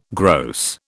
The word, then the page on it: gross: British English